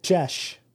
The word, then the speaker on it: Geas: G-E-A-S. – Pronunciation. Let's do this. Jish. Jish. – Like an hard G and an E like the word bed. Jesh. – What about G's like peas? – Kim, the spell is called geese.